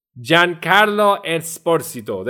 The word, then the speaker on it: Giancarlo Esposito: Giancarlo Esposito. Giancarlo Esposito. There you go. That's his name, and that's how you say his name.